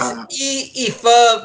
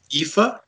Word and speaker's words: Aoife: It's written in Gaelic, so... – Yeah, it's Aoife Valkyrie. – Aoife? Aoife. Aoife Valkyrie.